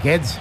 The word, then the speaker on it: Guedes: Y el más sorprendente, ahora lo comentaremos, fue el de Gonçalo Guedes, porque no lo vais a poder pronunciar. – Sí, es Guedes. – Gonçalo Guedes. Parecía alemán, más que portugués.